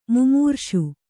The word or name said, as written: ಮೈಮರೆಸು